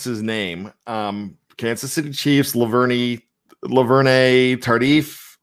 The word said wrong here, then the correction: Duvernay-Tardif: Laurent Duvernay-Tardif.